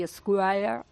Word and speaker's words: Esquire: Jorge Alcalde es el director de las revistas Cuo y Esquire, y además es divulgador científico de COP.